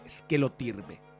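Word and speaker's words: esquelotirbe (σκελοτύρβη): llamaba esquelotirbe en griego, sería la pronunciación, que quiere decir más o menos desorden de las piernas.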